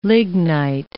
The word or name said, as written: lignite